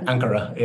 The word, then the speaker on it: Ankara: I guess it was through Bodrum down to, and I'm going to pronounce it wrong, Ankara. – Yeah, Ankara. Yeah.